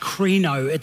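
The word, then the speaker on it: krino (κρίνω): The Greek word is pronounced krino.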